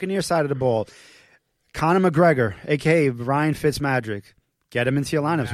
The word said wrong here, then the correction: Fitzmagic: Madrick? – Magic. Fitzmagic. Sorry.